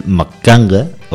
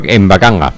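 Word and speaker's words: mbaqanga: Atentos a mi pronunciación, el Makanga o... – Mbakanga.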